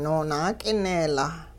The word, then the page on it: noonaak'ineelaa: Hupa